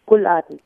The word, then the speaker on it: Rouladen: Rouladen, yes. – But it's spelled Rouladen. I was going to say Rouladen, but you don't pronounce the R so much.